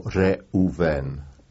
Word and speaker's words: Reuven: But when we were called in synagogue before the Torah, we were called by the name Reuven, and that was accepted too. – How do you spell that? – Reuben? Well, you could spell R-U-B-E-N, Reuben, or Reuven, R-E-U-V-E-N.